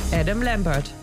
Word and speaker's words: Adam Lambert: Adam Lambert.